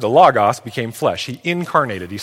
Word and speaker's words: logos: This word, there's no other way to say that, is a word in the Greek that is this, logos. Everybody say logos. We have a tendency in the English to pronounce it logo, like the Nike logo, but it's logos, okay?